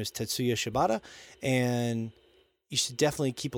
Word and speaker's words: Tetsuya Shibata: Tetsuya Shibata.